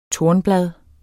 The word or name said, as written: tornblad